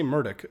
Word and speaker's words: Murdoch: I say Murdoch, ladies and gentlemen, because that's actually how the Murdochs pronounce it. They're Australian. You realize this is Rupert Murdoch. If you ever got a chance to hear Rupert Murdoch say his own name, that's the actual correct pronunciation, at least in his own native tongue.